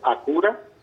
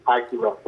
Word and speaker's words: Acura: Pues mira, la connotación en español pues es Acura. – Uh-huh. Pero gracias. – En inglés, pues es Acura por la...